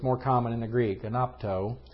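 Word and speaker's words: anapto (ἀνάπτω): And that word that's underneath the idea of being of set aflame is anapto. Anapto, that's the Greek word that's there. Anapto. A-N-A-P-T-O, long O, anapto.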